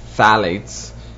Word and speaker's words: phthalates: Then the other one is phthalates, which is spelled with a P, phthalates.